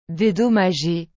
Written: dédommager

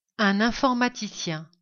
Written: un informaticien

